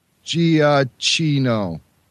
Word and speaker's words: Giacchino: It's uh, a G-I-A-C-C-H-I-N-O. M-O-U-S-E. – That's I mean, what you have to say. – yeah, I mean, the Italian would not pronounce it with a hard G. The Italians would pronounce it with a J, a soft G, Giacchino.